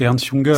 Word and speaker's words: Ernst Jünger: s'appelle Ernest Kunger, comment vous prononcez-vous? – En fait, c'est Ernst Jünger.